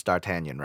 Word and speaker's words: D'Artagnan: It took me years to pronounce D'Artagnan right.